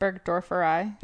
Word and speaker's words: burgdorferi: Burgdorferi.